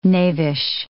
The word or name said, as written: knavish